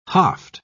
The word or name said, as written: haft